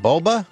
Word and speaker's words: Boba: Boba, huh? That's how you pronounce it? Boba? – Yes.